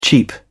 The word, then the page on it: cheep: British English